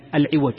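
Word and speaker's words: عِوَج: هذه اللفظة الأفصح فيها أن تنطق بكسر العين العوج، لأن هناك فرقا بين العوج وبين العوج.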